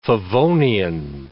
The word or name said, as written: favonian